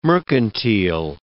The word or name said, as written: mercantile